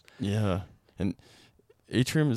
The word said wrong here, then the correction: Antrim: is that how you say it? Atrium or Antrim Point? – Atrium? Antrim. – Antrim, Antrim.